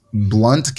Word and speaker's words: Blount: It's funny because it's Blount County, but it's spelled Blount, Blount.